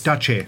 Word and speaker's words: Duchy: Duchy.